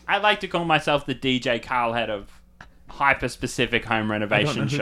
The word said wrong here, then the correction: Khaled: I don't know who that is. DJ Khaled. – Have I been saying it wrong this whole time? – You don't know?